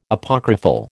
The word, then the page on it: Apocryphal: American English